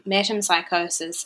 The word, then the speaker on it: metempsychosis: Could we talk about, is it metempsychosis? Is that yes. How you say it? – I believe that is how you say it, metempsychosis.